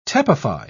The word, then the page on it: tepefy: British English